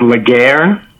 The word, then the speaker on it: Laguerre: The last name is Laguerre. It's French, and it means the war.